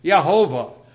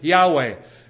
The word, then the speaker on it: Yahweh: Now, Tyndale and his friends came up with an idea pronouncing it Yehovah. Or some of the later scholars say it maybe would be better to pronounce it Yahweh.